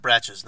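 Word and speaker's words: Brach's: It is pronounced Bratches, not Brox.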